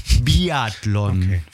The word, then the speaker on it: Biathlon: Bi- oder Biathlon? – Danke für die Nachfrage, dann war meine Aussprache nicht richtig. Biathlon. – Okay, das R dazwischen, tut mir leid.